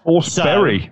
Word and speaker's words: Forsberg: Forsberry, so, according to UEFA. – Yeah, this is one of those, right? – It's more like Forsberia, isn't it?